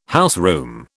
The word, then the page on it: houseroom: British English